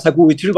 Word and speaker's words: sacubitril: Regarding your article about, and I'm probably gonna pronounce this wrong, sacubitril and valsartan, these two Correct. – Drugs. Can you pronounce them, please? – Yes, sacubitril valsartan.